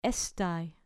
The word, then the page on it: estai: grec